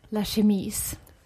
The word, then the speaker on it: Chemise: Jag kör en skjorta från La Chemie och ett par jeans från... – Uttalar man det så? – Nej, tror jag. – La Chemise. – Nej. – Skjorta på franska heter Chemise. Ja.